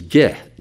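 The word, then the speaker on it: ge (γῆ): The word earth in Greek is ge, G-E, long E, G-E.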